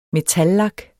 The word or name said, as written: metallak